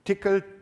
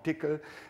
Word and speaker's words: Tcl: Und TK ist für eine Programmiersprache Tickle, TCL geschrieben, gesprochen Tickle. Wenn Sie das irgendwann im Leben wieder anders aussprechen, weiß ich genau, dass Sie nicht in dieser Vorlesung waren.